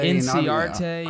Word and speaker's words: Inciarte: How does he pronounce his name? – Ender Inciarte. – Inciarte.